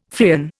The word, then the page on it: Freon: Indian